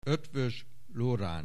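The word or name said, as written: Eötvös Loránd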